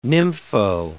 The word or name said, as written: nympho